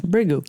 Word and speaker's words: Bhrigu: Of the great sages, I am Bhrigu. That's how you say it? – Yes. – Bhrigu.